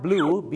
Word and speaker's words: blew: blue